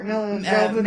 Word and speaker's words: Jovovich: How do you say it, uh, Milla Jovovich.